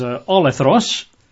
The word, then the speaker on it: olethros: This one is olethros, which is spelt in English, if you're taking notes, as my good wife is, O-L-O-L-E-T-H-R-O-S.